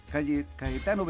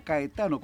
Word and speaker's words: Caetano: Y en cuanto a Cayetano entre las personalidades conocidas, Cayetano Veloso, puede ser Cayetano o Caetano, como se escribe en Brasil, en portugués, Caetano,